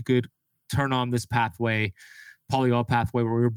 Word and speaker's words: polyol: Is it called the polyol pathway? – Yeah.